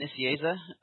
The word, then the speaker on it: Nicieza: Today we have Fabian Nicieza joining us. Did I say that correctly, Fabian? – Said it perfectly.